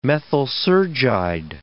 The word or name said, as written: methysergide